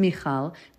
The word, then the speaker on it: Michal: It's Michal, but I say Michael because most people would pronounce it that.